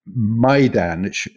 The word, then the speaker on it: Maidan: He goes on to say that the M A I in Maidan, i.e., the square in uh, in Kiev, where we were the other day, uh, is pronounced just that, just like that, my. Not, he says, like a distraught, alluring maiden waiting to be rescued by a knight of the round table.